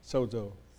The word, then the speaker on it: sozo: Make a friend of the word by saying it sozo. It's S O Z O. And, and Z's in Greek are pronounced Z. Sozo. With a D in it.